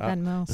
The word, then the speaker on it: Venmo: I mean, do you take Vimo? – I thought it's Venmo. – It's Venmo. Is it Venmo? – It's Venmo.